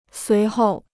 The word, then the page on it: 随后: suí hòu